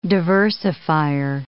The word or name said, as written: diversifier